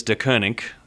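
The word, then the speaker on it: Deceuninck: How do we pronounce this new sponsor? – As far as I know, it is De Koenig. That's from Twitter.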